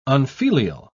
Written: unfilial

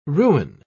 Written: ruin

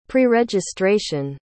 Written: preregistration

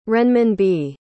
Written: renminbi

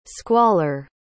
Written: squaller

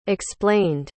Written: explained

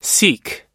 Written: sikh

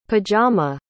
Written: pajama

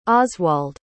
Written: oswald

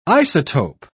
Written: isotope

